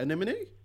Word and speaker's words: Anemone: That's, how do you actually say that? Anemone? – Yep, that is how you say it. Look, the N's and the M's get very confusing sometimes.